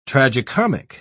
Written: tragicomic

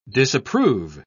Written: disapprove

